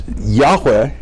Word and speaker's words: Yahweh: and it's Yahweh would be the best pronunciation of it.